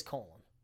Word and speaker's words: Colin: It is Colin.